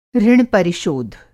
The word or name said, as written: ऋण-परिशोध